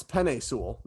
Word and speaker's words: Penei Sewell: So we've been saying his name wrong. It's Penei Sewell. Not it is Penei. – Penei. Yeah, like Penei.